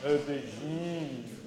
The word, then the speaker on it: aubergine: You have to pronounce it with a French accent. Aubergine.